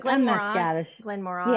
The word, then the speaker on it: Glenmorangie: Glenmorangie.